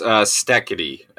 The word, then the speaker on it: Steketee: It is pronounced Stekete, S-T-E-K-E-T-E-E, Steve Stekete.